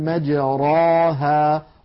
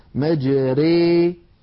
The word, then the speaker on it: مجراها: لو قبل الإمالة كنا نقول مجراها راها، لأن الراء مفتوحة، ومر معنا أن الراء مفتوحة مفخمة، ولكنها لما أميلت لم يعد التفخيم يناسبها، فصار النطق براء مرققة مجري ري، لاحظوا أنها مرققة.